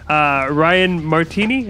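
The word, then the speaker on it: Ryan Martinie: For a bass player, I actually took, from Marcus evidently, Ryan Martini. Is that how you would say his name? – Yeah, he would have been up there on my...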